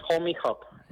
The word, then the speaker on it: Homyhub: Eh, y nuestro nombre es Homie Hop. Eh, Homie se escribe H-O-M-Y H-U-B. H-O-M-Y-H-U-B. Homie Hop.